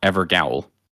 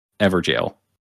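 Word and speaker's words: Evergaol: I pronounced Everjail, Evergowl. And I would say I received somewhere around 1,000 comments telling me to pronounce it Everjail.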